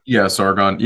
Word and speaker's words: Sargon: Yeah, Sargon.